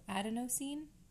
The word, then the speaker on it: adenosine: Um, so, the thought is that um, it's going to boost energies because it affects something called endonosine, which we didn't go up and look at the pronunciation, but it's, no, adenosine. Eh, a D E N O S I N E.